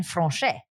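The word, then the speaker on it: Française: It's gonna be like chicken franchise or something. – Chicken french fries? – No, see, again, the proper pronunciation is chicken franchais.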